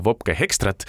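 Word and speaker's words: Wopke Hoekstra: intervjuude käigus, eks ole, tekkisid, tekkis ka küsimusi ja ma alustangi kohe nendest küsimustest selles osas just, mis puudutas, ma loodan, ma jälle ütlen õigesti seda Vopke Hekstrat ja tema paranda mind, kui ma väga valesti hääldan.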